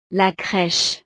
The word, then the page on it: la creche: frances